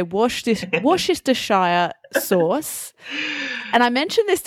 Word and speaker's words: Worcestershire: We don't kind of pronounce a hard C. So we would say Worcestershire. – Worcestershire.